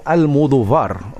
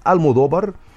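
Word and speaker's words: Almodóvar: أنا أود أن أرى كيف يرى العرب بيدرو المودوفار، ويسمونه المودوفار، وهذا بالطبع يعني النطق كما يبدو في حرف الفاء ربما ينطقونه كذا حسب شكل الكتابة، لكن في الحقيقة هو بالإسبانية ينطق المودوفار، والتي تأتي من العربية كما ذكرنا المدور.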